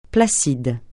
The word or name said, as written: placide